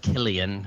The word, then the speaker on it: Cillian: Maybe it's pronounced Killian.